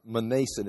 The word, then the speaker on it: Manasseh: I looked this up how to pronounce it. Manasseh. It's just the M.